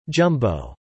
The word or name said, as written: jumbo